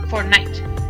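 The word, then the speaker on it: fortnight: Y sería Fortnite, ¿vale? ¿Cómo se pronuncia? Casi igual que el videojuego. ¿Vale? – Es que va a decir, es como el juego. – Sí, pero tiene una T, realmente.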